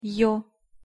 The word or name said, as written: ё